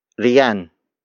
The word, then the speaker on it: riyan: The next word is riyan, riyan, riyan. Riyan is spelled as R-I-Y-A-N.